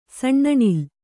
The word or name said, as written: ಸಣ್ಣಣಿಲ್